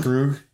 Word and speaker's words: Krug: They gave, how do you say his last name? Spencer Krug? Krug? – Krug. Yeah. – I think about like, yeah, like Kruger National Park, Krug, mm-hmm. Krug.